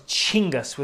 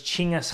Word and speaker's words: Chinggis: And actually, his name was Chinggis with a C-H, Chinggis Khan. And the K is silent, so it's Chinggis Khan is how you pronounce that.